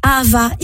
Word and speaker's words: Ahava: Vous avez un dernier coup de cœur, c'est Ava, évidemment. – Oui, vous connaissez, je pense, cette marque. Donc, ça s'écrit A-H-A-V-A.